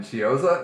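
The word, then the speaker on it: Chiozza: And then Chioza, is that how you say his name? – Oh, yeah.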